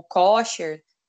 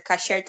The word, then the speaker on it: kosher: ou kosher, kosher, kasher tá certo, pode ser seus, várias pronúncias, o Abadhalal e o, e o Kosher, eles são, é, digamos, têm semelhanças, né?